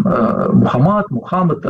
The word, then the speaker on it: Мухаммад: Допустим, Мухаммад, разные тоже произношения.